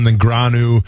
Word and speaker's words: Negreanu: Dalen Negreanu, Doyle Brunson.